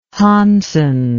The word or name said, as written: Hansen